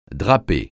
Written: draper

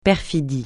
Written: perfidie

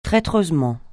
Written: traîtreusement

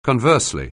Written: conversely

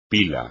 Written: pila

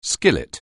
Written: skillet

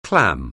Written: clam

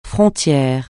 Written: frontière

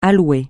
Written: à louer

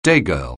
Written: daygirl

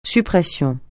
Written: suppression

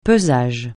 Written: pesage

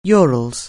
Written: Urals

